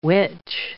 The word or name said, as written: witch